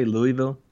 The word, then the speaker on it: Louisville: And how do you say Louisville? I say Louisville. Do you say Louisville? – I do sometimes say Louisville.